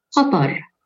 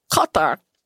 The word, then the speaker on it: Qatar: It's not an emphatic k noise at all. – Better. – Something like that. The second syllable of the word has a dark twist. – It is. Oh. So the accurate pronunciation of the name of the country is Qatar.